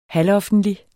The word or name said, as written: halvoffentlig